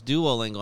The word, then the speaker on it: Duolingo: And I like said it wrong. She's like, no, it's Duolingo. I'm like, oh, I'm getting my Spanish wrong already. And she's like, well, duo isn't a Spanish term.